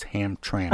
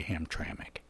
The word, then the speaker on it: Hamtramck: Oh, please let me interrupt for just a moment and correct your pronunciation. It's Hamtramck. – I know. – And I'm only doing that in the spirit of friendship. You've been so gracious to teach me how to pronounce some things. And I am familiar with that town, and it's pronounced locally Hamtramck.